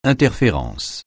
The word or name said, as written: interférence